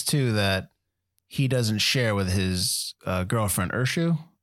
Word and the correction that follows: Usha: right? As I said that right? Usha, excuse me.